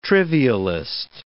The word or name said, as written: trivialist